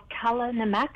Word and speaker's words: kala namak: It's called kala namak. It's spelled K-A-L-A-N-A-M-A-K. Kala namak.